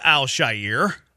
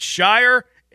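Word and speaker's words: Al-Shaair: It's not Al Shire, and it's not Bullock. – Yeah. – It's Shire, and it's Bullock.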